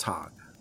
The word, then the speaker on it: Tog: And I'm my tribe is called Tog. – Todd? – Tog. – Tog. Okay. Rhymes with bog.